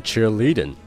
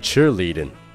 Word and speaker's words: cheerleading: 哎，那在这个地方注意一下，这个 cheerleading 的重读是在低音节，也就是在 cheer 这个词上。所以说，不要读成 cheerleading，而是 cheerleading。